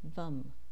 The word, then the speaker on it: Vam: Its beach mantra is Vam, phonetically spelled as V-U-M.